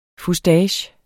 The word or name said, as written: fustage